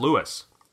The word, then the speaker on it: Lois: This person's first name is spelled L-O-I-S, which is how we spell the female name Lois in North America, but in French, it's a male name and pronounced Louis.